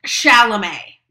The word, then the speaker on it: Chalamet: It's pronounced Chalamet.